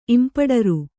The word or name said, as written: ಇಂಪಡರು